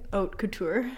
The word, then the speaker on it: haute couture: Haute couture.